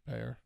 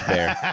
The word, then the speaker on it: Bexar: Bayer. – Silent X. – Okay, Bexar, it is, you're right. I was confused when I heard the news person say it, and I was like, oh, this idiot's not, he's pronouncing it wrong. But it is Bear. Okay. Who writes Bear, B-E-X-A-R?